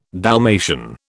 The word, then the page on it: Dalmatian: British English